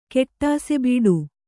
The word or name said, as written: ಕೆಟ್ಟಾಸೆಬೀಡು